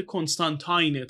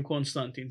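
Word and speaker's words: Constantine: از کامیکای سندمنه اونجا هم جان کنستانتین البته کنستانتاینه تلفظ خودشیز ما میگیم کنستانتین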